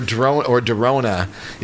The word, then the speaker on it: Drona: or Drona. It's D-R-O-N-A. These are some younger band mates from, I think, Hickman. But I think it's pronounced Drona, like it's a play on coronavirus.